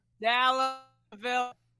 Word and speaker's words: Dalla Villa: Did I say that right? Villa? – Dalla Villa. Dalla, it's okay. Villa. Everyone fucks it up, so it's okay.